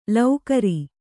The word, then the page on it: ಳಕಾರ: Kannada